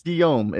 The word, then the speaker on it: Guillaume: It's Guillaume.